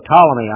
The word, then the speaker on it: Ptolemy: Well, the Ptolemaic line, and I think the P is silent. It's spelled P-T-O-L-E-M-Y. So Ptolemy, I call it. It might be Ptolemy.